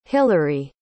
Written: hilarie

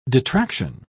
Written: detraction

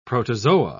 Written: protozoa

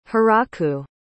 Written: hiraku

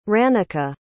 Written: Ranica

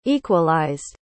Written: equalized

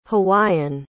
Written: hawaiian

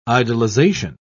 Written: idolization

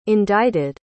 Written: indited

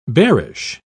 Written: bearish

